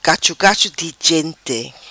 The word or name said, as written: Cacho Cacho Di Gente